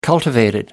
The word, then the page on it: cultivated: American English